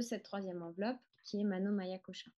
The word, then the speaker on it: Manomaya Kosha: Euh, je prononce tous les A, mais en vérité, on, n'est pas, on, peut ne pas prononcer le, dernier A de Maya. Peut-être que vous avez déjà entendu Mano Maya Kosha, c'est possible aussi.